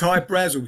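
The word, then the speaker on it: Hy-Brasil: People pronounce it High Brazil, and it's Brazil. Okay. – yeah, but it's High Brazil because that's how the Irish would pronounce it, because it's an okay.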